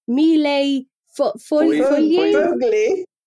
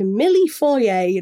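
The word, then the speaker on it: millefoglie: An Italian millefeuille, which is a mille foglie. – Foglie. – I swear at one point Paul just said milli foyer, and I was like, I'm pretty sure that's not quite right.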